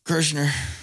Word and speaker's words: Kreischer: Kreischer.